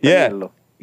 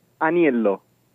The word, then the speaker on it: Aniello: Yeah, it's Aniello. – Yeah. Say it again. – Aniello.